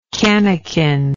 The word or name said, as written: canikin